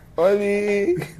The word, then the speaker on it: ollie: Oli. – Pero, pero, pero, pero, la Oli, la Oli, la- el Oli viene de Ola. – No, No, de hecho se, llama- se escribe O-L-L-I-E.